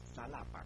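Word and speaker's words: Xalapa: Porque se escribe con X, es Jalapa.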